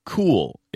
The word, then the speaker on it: Cuil: A new search engine called Cool is open for business. Cool is spelled C-U-I-L.